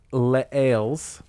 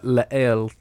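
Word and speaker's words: Lesailes: But it's an artist named Laels. You have to say it sort of French. It's L-E-S-A-I-L-E-S. So it looks like Les Ailes if you just say it like a southerner, but Laels, not sure.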